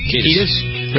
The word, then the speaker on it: Kiedis: Kiedis.